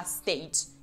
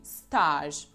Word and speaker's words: stage: E voi potreste pensare, a parte che si pronuncia stage, e poi io voglio lavorare in un ufficio, mica voglio fare l'attore. Errore! In italiano, infatti, questa parola primo si pronuncia alla francese, e quindi stage, e secondo indica proprio quello che voi state cercando.